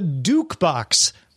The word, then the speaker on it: Duke Box: The Duke Box rhymes with jukebox.